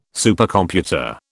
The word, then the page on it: Supercomputer: British English